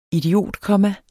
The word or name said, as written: idiotkomma